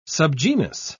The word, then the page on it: subgenus: British English